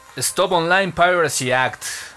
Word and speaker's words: Stop Online Piracy Act: SOPA, Stop Online Piracy Act.